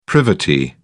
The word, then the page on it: privity: British English